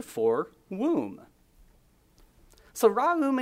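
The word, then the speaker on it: ra'um (רחום): Now, this is the Hebrew word ra'um, ra'um. If you want to spell it, it's spelled R-A-K-H-U-M, R-A-K-H-U-M. From what I understand, it's pronounced ra'um.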